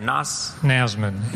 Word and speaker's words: Nasman: Nasman. – N-A-S-M-A-N. – Yeah. – Like Hazman.